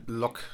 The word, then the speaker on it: Locke: Locke, oh, spricht man auch ohne E, gell? – Locke.